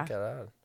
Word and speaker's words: encarar: Encarar la defensor. – Encarar. Ooh. – Did I say that right? – Yeah. – Yeah. – Encarar.